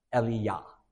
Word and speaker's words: Elijah: And in Hebrew, Elijah is pronounced Eliyah. So they're very similar, right? Eli, Eliyah.